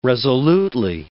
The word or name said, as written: resolutely